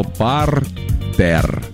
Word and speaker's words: партер: Есть такое слово «партер». Слышали наверняка. – Да, да, да, да. – Так, как же все-таки правильно говорить? «Партер» или «партер»? Куда «партер»? – Ставить?